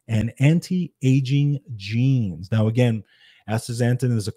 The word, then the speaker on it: astaxanthin: So let's talk about astaxanthin.